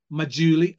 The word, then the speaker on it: Majuli: Just Keep Buying by Nick Majuli, and that is the pronunciation, that correct one, I've got it correct eventually, and Investing for Growth by Terry Smith, and Invest in Your Way to Financial Freedom by Robin Powell and Ben Carlson.